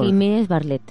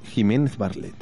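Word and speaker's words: Giménez Bartlett: Jiménez Barlet. – Jiménez Barlet. Qué bien lo dices tú.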